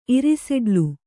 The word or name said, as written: ಇರಿಸೆಡ್ಲು